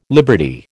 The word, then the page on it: liberty: American English